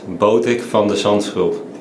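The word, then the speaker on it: Botic van de Zandschulp: I mean, his last name is spelled Zandschulp. So reporters at the U.S.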